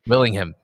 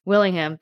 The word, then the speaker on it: Willingham: Willingham. – Willingham, thank you.